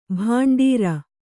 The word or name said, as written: ಭಂಡಾರಿ